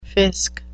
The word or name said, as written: fisc